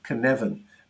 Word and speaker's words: Cynefin: uh, it's spelt C Y N E F I N, Cynefin, but it's pronounced Cynefin. Uh, mm. Because, like I said, it's a Welsh word.